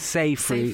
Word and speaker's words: Seyfried: Seafried for sure. – Okay. I hope I'm saying her name right. Seafried. – I thought it was Seyfried.